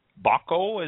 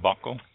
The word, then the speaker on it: Baco: Oh, Bocco, is it pronounced? – Mm-hmm. – Bocco? – Yep. Now, we didn't put a lot of Bocco in. We only put 100 vines of Baco in, and we used it more for blending.